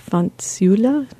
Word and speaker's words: fanciulla: "O suave fanciulla."